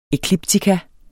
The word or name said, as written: ekliptika